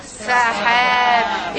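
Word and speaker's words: Sahab (سحاب): I want you to pronounce the long vowel. Sahab.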